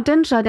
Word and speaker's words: Dinçer: Den Namen nicht richtig aussprechen. Dinscher Güeta. – Ja, genau, Dinscher, der ja, ja.